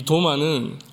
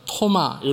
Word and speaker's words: 도마: 이 도마는 아람어입니다. 그래서 토마 이렇게 발음하기도 하는데, 그 뜻은 쌍둥이라는 의미를 가지고 있습니다.